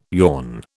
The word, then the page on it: yawn: British English